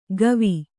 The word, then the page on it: ಕವಿ: kavi